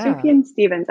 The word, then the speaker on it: Sufjan Stevens: Yeah, Sufjan Stevens.